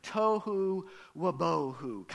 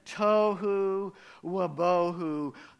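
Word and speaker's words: Tohu wabohu (תהו ובהו): Tohu wabohu. Kind of has a nice ring to it, doesn't it? Tohu wabohu. Say it with me on the count of three. One, two, three. Tohu wabohu.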